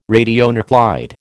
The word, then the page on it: radionuclide: American English